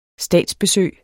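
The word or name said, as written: statsbesøg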